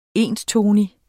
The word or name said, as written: enstonig